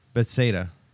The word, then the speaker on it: Bethsaida: He came from a family of fishermen in Bethsaida. Did I say that right? Bethsaida. That's what I meant.